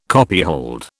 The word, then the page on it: copyhold: British English